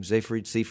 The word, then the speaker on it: Seyfried: Is that how you say her name? Seyfried? She used to be in Big Love on television. – Siegfried and Roy. – There's no G.